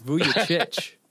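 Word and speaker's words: Vučević: Hey, if you can't say his name, you can't have him. It's Vucevic.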